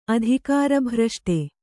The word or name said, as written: ಅಧಿಕಾರಭ್ರಷ್ಟೆ